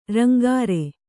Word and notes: ರಂಗಾರೆ: rangāre

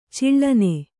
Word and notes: ಚಾಳನಿ: cāḷani

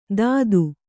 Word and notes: ತತ್ತು: Kannada

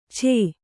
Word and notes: ಛೇ: chē

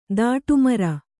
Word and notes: ದಾಟುಮರ: dāṭu mara

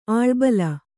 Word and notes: ಅಳಬಳ: aḷabaḷa

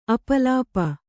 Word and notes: ಅಪಲಾಪ: Kannada

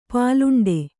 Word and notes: ಪಲಾಂಡು: palāṇḍu

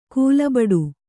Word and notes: ಕಲ್ಲುಬೆಟ್ಟ: kannada

